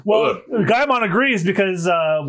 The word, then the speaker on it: Gaimon: It's it's pronounced Gaimon, the keeper of the ah, island. Ah, Gaimon.